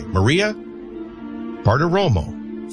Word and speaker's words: Maria Bartiromo: Birthdays, Ludacris, 49, Harry Connick Jr., 59, and maybe the next White House press secretary, Maria Bartiromo, 59.